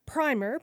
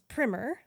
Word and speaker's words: primer: By the way, if you're like me, you might not have heard primer pronounced as primer, but it turns out historically primer was the pronunciation used to refer to an introductory book on a subject, whereas primer was the pronunciation used to refer to a pretreatment.